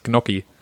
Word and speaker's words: Gnocchi: Nach meinem Kenntnisstand sind das Gnocchi.